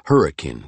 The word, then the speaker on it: Hurricane: He was the water boss in Hurricane, which the locals pronounced Hurricane for reasons no one quite remembered.